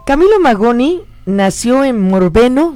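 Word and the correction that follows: Morbegno: no sé si lo digo bien. – Morbeño. – Morbeño. Morbeño, Italia. Esa, la N se pronuncia como Ñ en italiano.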